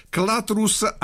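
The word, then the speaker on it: Clathrus: Si chiamano Clatrus Arcieri. Si pronuncia così, ma si scrive in realtà Clatrus col TH e Arcieri si scrive Archeri. Ok, ma bene. – Si pronuncia Arcieri.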